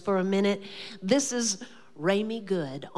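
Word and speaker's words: Remi: It's R-E-M-I. – Yes. – It's pronounced Raimi, but it's R-E-M-I. Good with an E at the end.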